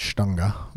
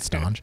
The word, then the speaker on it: Stange: So, it is a uh, tray that holds uh, Stange, which actually, if you're German, would be pronounced Stunga, but um, I'm not German, so I pronounce it staunch.